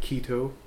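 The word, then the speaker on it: chaeto: Is that Kaito? – Different people pronounce it different ways. I think keto. – Oh, okay. – Is the way I've heard it most of the time.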